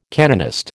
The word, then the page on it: canonist: American English